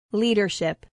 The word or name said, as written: leadership